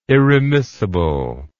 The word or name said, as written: irremissible